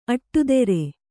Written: ಅಟ್ಟಿದಿರು

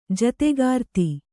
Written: ಜೊತೆಗಾರ್ತಿ

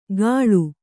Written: ಕಳು

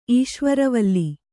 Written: ಈಶ್ವರವಲ್ಲಿ

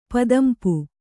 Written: ಪದಂಪು

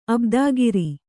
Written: ಅಬ್ದಾಗಿರಿ

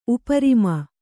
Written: ಉಪರಿಮ

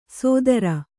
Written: ಸೋದರ

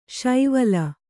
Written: ಶೈವಲಿ

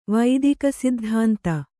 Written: ವೈದಿಕಸಿದ್ಧಾಂತ

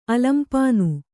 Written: ಅಲಂಪಾನು

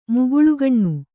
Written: ಮುಗುಳುಗಣ್ಣು

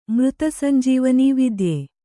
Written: ಮೃತಸಂಜೀವನೀ ವಿದ್ಯೆ

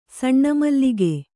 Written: ಸಣ್ಣಮಲ್ಲಿಗೆ

